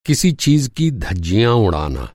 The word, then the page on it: धज्जी: دھجی